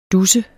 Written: dusse

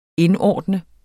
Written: indordne

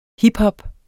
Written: HIPHOP

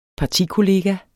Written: partikollega

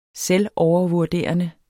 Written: selvovervurderende

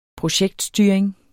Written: projektstyring

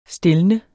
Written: stilne

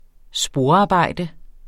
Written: sporarbejde

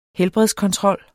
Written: helbredskontrol

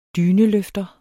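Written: DYNELØFTER

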